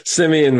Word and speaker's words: Semyon: they've shifted away from Semeny Varlamov, their guy who they pay. What's it? Did I butcher the name? – Semyon Varlamov.